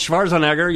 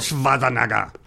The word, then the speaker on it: Schwarzenegger: Terminator Genesis kind of made us think about Arnold Schwarzenegger. You have to say his name like that if you have a podcast. Arnold Schwarzenegger.